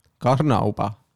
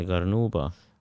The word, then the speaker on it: Karnauba: Äh, karmivaha, Karnauba. aga hääldatakse Karnuba. kindel on? jaa, sada protsenti.